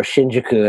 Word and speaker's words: Shinjuku: It's going to be part one of a three-parter, and we're going to focus in these next three episodes on the one and only Shinjuku, or Shinjuku as it's really meant to be pronounced.